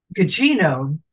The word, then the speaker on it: Gugino: Though, Gugino, did I pronounce her name right? – I think that's right. – I'm always a little uncomfortable. It seems like hard G, soft G.